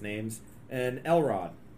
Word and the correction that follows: Elrond: So Galadriel, Elrond, and Sauron all going to be there.